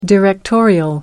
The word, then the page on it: directorial: American English